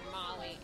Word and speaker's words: Molly: That's like when I was growing up, my parents were like, oh, tell people Molly. – Right. – So they can pronounce it. – Right. – So my whole life, my name has been Lynn Molly in my head. – Right. – But then when I see Lynn, M-O-L-L-Y, written on paper, I'm like, well, that's not it.